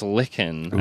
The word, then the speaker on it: lichen: going to be like really, or is it lichen? Apparently, it's pronounced lichen.